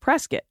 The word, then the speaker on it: Prescott: Prescott, Arizona is pronounced Prescott, like biscuit. Yeah, I don't know why either, but it is.